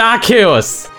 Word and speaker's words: Arceus: und damit herzlich willkommen bei Pokémon Legenden Arceus. Arceus. Das heißt Arceus.